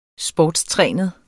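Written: SPORTSTRÆNET

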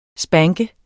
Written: spanke

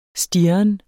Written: stirren

